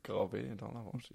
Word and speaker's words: Gravé dans la Roche: Ist das richtig ausgesprochen? – glaube, Gravé dans la Roche. Ist nicht ah, er sagt das EE eh, eh gestimmt. Ja, ja, wenn du darauf passt. Gravé äh, dans la Roche.